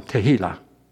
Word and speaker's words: Tehillah: Here's the last one, Tehillah. I'll spell it. T-E-H-I-L-L-A-H.